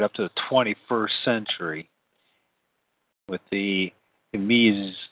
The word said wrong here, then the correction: Meiwes: case. I don't know if I pronounced that right. It's Meiwes.